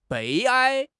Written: 悲哀

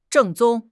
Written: 正宗

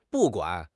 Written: bu4 guan3

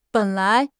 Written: ben3 lai2

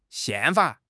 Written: 宪法

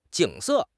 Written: jing3 se4

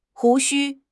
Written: 胡须

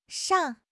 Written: shang4

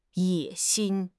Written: 野心

